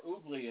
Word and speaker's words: Ugli: No, it's spelled UGLI, ugly, or oogly, it's pronounced.